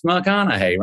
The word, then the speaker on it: McConaughey: If I work out and get successful, people will learn how to say it because, you know, it's McConaughey. Rhymes with what would Madonna say?